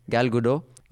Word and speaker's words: Gal Gadot: So I think Gal Gadot, have I got the name right? Gal Gadot.